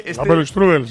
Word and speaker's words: Apfelstrudel: También nos dicen por aquí un dulce típico de manzana alemán, que, a ver si soy capaz de pronunciarlo, Apfelstrudel. Que Apfelstrudel, señor.